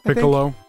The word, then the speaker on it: Piccolo: Piccolo.